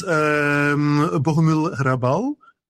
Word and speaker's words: Bohumil Hrabal: Well, for instance, Bohumil Hrabal, I like a lot. Do I pronounce it correctly? Or? – Very good pronunciation.